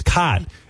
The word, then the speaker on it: Kotb: Well, K-O-T-B does not spell anything. It's not Kotb. It's cot with a silent B. If it's Kotb, it should be K-O-T-B-E-E. Kotb. – Well, but anyway, comb is C-O-M-B, and you don't say the B.